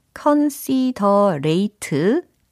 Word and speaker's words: considerate: C-O-N-S-I-D-E-R-A-T-E e 이겁니다. 발음은 어떻게 될까요? considerate 아니고요. considerate, considerate 이렇게 해주시면 되겠습니다.